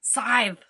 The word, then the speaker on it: scythe: We've got a skeleton here, totally bare bones, and has, with a scythe... Am I saying that right? Scythe? – Scythe.